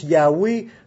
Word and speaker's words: Yahweh: Mais ça se prononce Yahweh dans les syllabes hébreux. On prononcera ça Yahweh, ces quatre lettres-là.